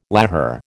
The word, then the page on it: lahar: American English